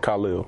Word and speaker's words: Khalil: First name, pronounce it Khalil.